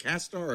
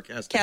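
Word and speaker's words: CastAR: Am I saying it right? Is it Cast-R or Cast-AR? – Cast-AR. – Okay.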